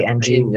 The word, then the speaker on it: Ng: The founders were Andrew NG. I'm just going to say NG. Yeah, sorry if I'm saying that wrong. – I'd say Ng. Ng sounds right. Ng. – Yeah. It's spelled NG.